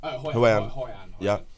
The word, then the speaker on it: Hoi An: Okay, so from Huan, am I saying that right? Hoi An. – Hoi An.